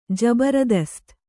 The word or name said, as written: ಜಬರದಸ್ತ್